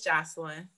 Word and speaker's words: Jocelyn: Yeah, it's Jocelyn.